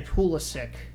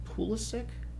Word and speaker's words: Pulisic: But at so yes, players, I mean, we could say Pulisic. Is that how I say it? Is that Pulisic? – Yeah, or Pulisic. I mean, Pulisic? – Either one.